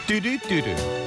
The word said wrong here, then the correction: 뚜두뚜두: 예, 사실은 뚜두뚜두예요. 뚜두뚜두. 네, 발음 안 되는 우리 아저씨들은 뚜루뚜루라고 계속 소개를 했었던 저도 계속 뚜루뚜루라고 얘기해서 수현이한테 많이 혼났던 오빠 뚜두뚜두야 그랬던 노래.